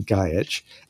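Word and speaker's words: Gajić: Gajic, the last name of the guest character in this episode, is the last name of Mira Furlan's husband, Goran Gajic. And that's the, even though it looks like it's spelled Gajic, it's pronounced Gajic. And I think that is the Croatian pronunciation.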